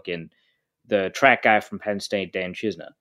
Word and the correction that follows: Chisena: uh, or Chisena, I guess is, we, have been told is how it's pronounced.